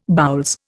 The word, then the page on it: boules: Indian